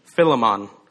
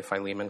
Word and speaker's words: Philemon: Uh, some people pronounce it Philemon. Uh, and I think that that's probably the most correct pronunci- pronunciation, but I'm just going to continue to say Philemon because that's what I grew up saying.